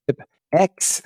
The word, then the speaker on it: pipx: Pip X, they always get this wrong, and it says pip. I don't know what this is about. Pip X install speedtest-cli. That is how we pronounce this. I know the X is invisible, but not silent.